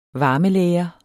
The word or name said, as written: varmelære